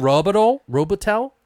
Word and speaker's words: Robitel: This movie was directed and written and also partially produced by Adam Robitel, I think. I don't know how to say his name right. I apologize. it's R-O-B-I-T-E-L. So, Robitel. I don't know. – Robitel? – Works for me.